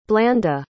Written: blanda

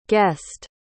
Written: guessed

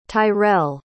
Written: tyrel

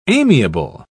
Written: amiable

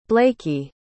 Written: blakey

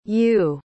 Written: Yue

